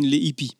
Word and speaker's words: Les Hippies: Les Hippies? – Nee, das H wird grundsätzlich so nicht ausgesprochen. – Okay, Les Hippies. – Also wir sagen Les Hippies.